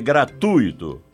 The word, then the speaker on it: gratuito: Como você pronuncia gratuito ou gratuito? O certo é gratuito, com U tônico. A sílaba tônica recai no U e não no I, e não leva acento gráfico.